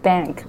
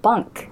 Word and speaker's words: bank: So and then I realized one day, that was the pronunciation of bank, B-A-N-K. And the time I didn't know the vowel, so I pronounced it bunk. – Oh, I see. – Right? – Different. – So bunk, bunk is B-U-N-K, right?